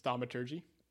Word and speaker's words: thaumaturgy: Thaumaturgy. – Thaumaturgy.